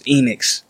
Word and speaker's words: Enoex: It's Enix. But it's spelled E-N-O-E-X.